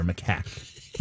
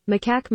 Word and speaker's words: macaque: Macaque, I believe, is the. I think it's an emphasis on the A there. Macaque. Let me play it here officially. – Macaque monkey.